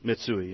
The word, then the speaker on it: Mitsui: Because, you know, I saw something the other day that said Mitsui, is that how you say it, Mitsui? – Mitsui, yes.